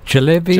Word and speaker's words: jalebi: One that comes up for me is, let me know if I'm saying this right, jalebi. – Jalebi. – Jalebi. – Oh, jalebi.